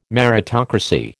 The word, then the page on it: meritocracy: American English